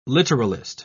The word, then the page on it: literalist: British English